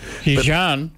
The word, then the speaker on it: Xi'an: I think it's Xi'an, X-I-A-N. I don't know if that's how you say it. – Xi'an? – Yeah, I think it is, but I'm going to click on Beijing because it's our first choice. No, I was right. It was Xi'an.